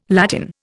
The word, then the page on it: Latin: Indian